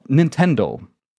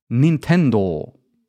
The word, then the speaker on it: Nintendo: And interestingly enough, you might have noticed this already, if you want to pronounce the name in the way that it's written in Japanese, then it's actually not Nintendo. The stress is a little bit different, and you have to prolong the O a little bit. So it would be pronounced as Nintendo in the original Japanese pronunciation.